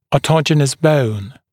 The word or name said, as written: autogenous bone